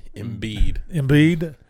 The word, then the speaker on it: Embiid: I don't know how to pronounce it. Embiid? – Embiid. Joel Embiid.